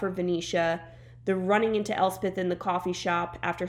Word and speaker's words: Elspeth: Elspeth.